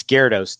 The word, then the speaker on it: Gyarados: From my childhood, I said Gyarados all the time. So Gyarados. Yeah, that's what my kids said too. I couldn't pronounce it either. And my son's like, it's Gyarados, Dad.